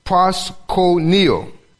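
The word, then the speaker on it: proskuneo (προσκυνέω): In the Greek, it's pronounced proskuneo. Pros meaning towards. Kuneo meaning to kiss.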